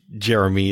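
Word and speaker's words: Ger'mee: I kind of like the idea that Jeremy is actually from, like, Alpha Centauri or something like that. – Well, it's spelled G-E-R apostrophe M-E-E, but it is pronounced Jeremy. With a very flat accent.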